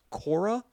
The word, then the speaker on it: Quora: But what I did find was a website called, I think it's called, the way it's pronounced is Quora, Q-U-O-R-A.